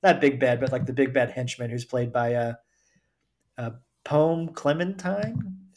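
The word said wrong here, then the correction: Klementieff: Is that how you pronounce her name? Clementief.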